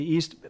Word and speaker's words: E4ST: And you use a tool, Dan, that you've developed called, I think it's pronounced the EAST model, but there's a 4 in there.